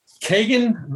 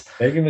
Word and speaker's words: Kagen: Kagen the Damned. – There it is. – Kagen the Damned.